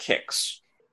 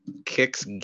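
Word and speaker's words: Qix: It kind of feels like really what this game kind of wants to be is, if you're familiar with a game that I think is pronounced Kix.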